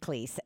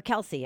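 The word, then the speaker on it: Kelce: Aaron Rodgers wants to have a debate with Kleese or Kelsey. Excuse me. I'm pronouncing it wrong.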